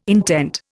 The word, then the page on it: intent: Indian